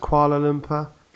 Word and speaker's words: Kuala Lumpur: To our American listeners, it's pronounced Kuala Lumpur. Get it right.